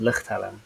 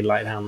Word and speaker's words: Lichthelm: Yeah, so it's Solomon, and it's then pronounced, my last name is pronounced Lichthelm. Good luck with that. – it's not Lighthelm, like the rest of us think. – You know what, like for the Americans, it can be Lighthelm, that's fine.